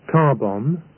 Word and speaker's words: carbon: The same is true for the word carbon, spelt C-A-R-B-O-N. It's not pronounced carbon, but carbon.